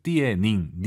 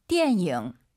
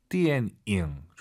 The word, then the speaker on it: 电影: 띠에닝, 니은 발음 들리면 안 돼요. – 띠엔잉. – 띠엔잉.